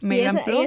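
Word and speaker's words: MedlinePlus: También Medline Plus, en español que se escribe Medline Plus, para que se entiendan, con la U, pero se pronuncia en inglés Medline Plus.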